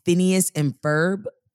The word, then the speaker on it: Phineas and Ferb: And Phineas and Ferb. Am I saying that right? – Yeah.